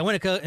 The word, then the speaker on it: Winnetka: The McAllister house was located at 671 Lincoln Boulevard in Winnetka. Winnetka? Is that how it's pronounced? – Let's go with Winnetka. – Yeah, Winnetka, Illinois.